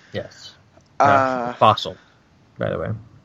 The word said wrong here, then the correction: Fassel: It's Fassel, I think, is how you say his last name. It's F-A-S-S-E-L.